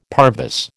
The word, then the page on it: parvis: American English